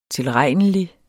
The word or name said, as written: tilregnelig